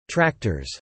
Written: tractors

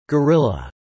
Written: gorilla

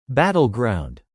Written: battleground

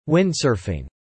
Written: Windsurfing

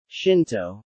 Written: Shinto

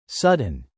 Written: sudden

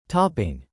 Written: topping

